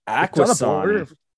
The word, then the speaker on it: Akwesasne: Akwesasne. Don't even pronounce the S.